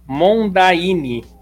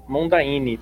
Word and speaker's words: Mondaine: ó e ó, ele explicou como é que fala aqui, ó. Mondaine, Mondaine. Pensa no relógio. Que relógio? Mondaine.